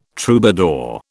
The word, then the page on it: troubadour: British English